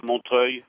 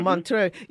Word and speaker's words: Montreuil: There's the yellow vest of Montreuil. Is that the correct pronunciation? – Montreuil. – Mm-hmm. Montreuil. Yeah, they say, and I'm quoting this, this is...